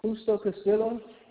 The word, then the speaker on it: Justo Castillo: Justo Castillo. – May you spell it for me? – J-U-S-T-O-C-A-S-T-I-L-L-O.